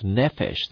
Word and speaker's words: nephesh: You see, there's a Hebrew word we pronounce nephesh that's used in Genesis for animals and man.